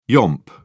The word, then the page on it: yomp: British English